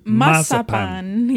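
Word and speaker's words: mazapán: Mazapan. – Is that what you call it? I call it marzipan, and you call it mazapan, yes. – Which is how you pronounce it in Spanish.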